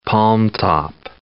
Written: palmtop

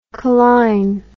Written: coline

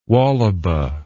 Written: wallaba

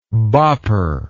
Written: bopper

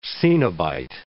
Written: coenobite

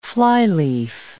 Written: flyleaf

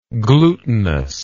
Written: glutinosity

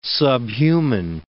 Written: subhuman

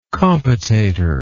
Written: compotatory

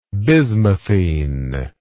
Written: bismuthine

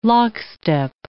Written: lockstep

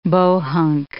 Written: bohunk

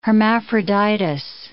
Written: Hermaphroditus

